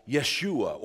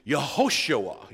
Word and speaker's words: Yehoshua: And yet if you were to say the name in Hebrew, you would not pronounce it that way. You would pronounce the name as Yeshua or Joshua, if we were to anglicize it. And actually, in its fullest expanded form, it would actually have four syllables. It would be Yehoshua, Yehoshua.